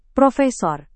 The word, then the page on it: prof: Indonesian